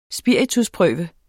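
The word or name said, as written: SPIRITUSPRØVE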